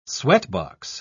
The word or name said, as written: sweatbox